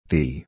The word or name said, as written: thee